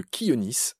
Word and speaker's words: Chionis: C'est qu'à la fin, il y a une photo d'un oiseau qui s'appelle le Kionis. Ça s'écrit C-H-I-O-N-I-S,